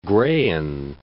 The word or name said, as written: Graian Alps